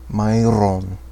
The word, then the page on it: Mairon: Quenya